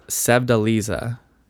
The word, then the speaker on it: Sevdaliza: Yeah, it's Sevdaliza is the name of it.